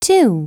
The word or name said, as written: 2